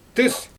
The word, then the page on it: tɯs: Xyzyl